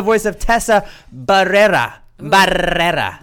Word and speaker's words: Barrera: That's how you say it, right? – That is exactly how you say it. – Is that really it? – 14 R's so, in that last name. – Barrera and Robert Barbosa. I gotta get good at rolling the R's.